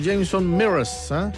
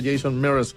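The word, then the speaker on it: Jason Mraz: Erika Rosana por acá nos estaba preguntando por Jameson Mears, pero es Jason M-R-A-Z, pero se pronuncia Jason Mears, el invitado en el especial musical ahí del Trasnoche.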